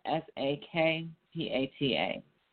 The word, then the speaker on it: Sakpata: Um, uh, Sakpata is spelled uh, S A K P A T A. Sakpata.